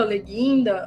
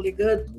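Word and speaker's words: Le Guin: Eu gosto muito da Úrsula Leguinda, ou Legand, conforme queira pronunciar.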